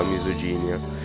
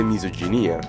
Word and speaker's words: misoginia: ho nominato la parola misoginia, senza sapere che, avendola letta per la prima volta, che la pronuncia corretta fosse misoginia.